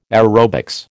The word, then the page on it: aerobics: American English